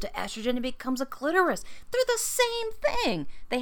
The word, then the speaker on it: clitoris: The clitoris. And you know what, people? Stop saying clitoris. I think that was a Seinfeld thing. Like Dolores, it had to rhyme with Dolores.